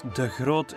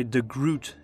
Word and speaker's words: de Groot: And yes, de Groot is how you pronounce my last name in Flemish, but I guess you would say de Groot in English,